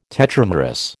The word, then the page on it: tetramerous: American English